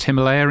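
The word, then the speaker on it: Ximalaya: By the way, I think it's spelt Zimalaya in China, but it's actually pronounced Himalaya in China as well.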